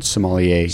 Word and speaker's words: sommelier: First one, will you please pronounce the word of your certification? – Sure. American Sommelier. – Sommelier.